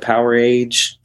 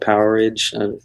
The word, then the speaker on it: Powerage: Some people call it Powerage. Some people call it Powerage. But it's spelled Powerage, one word.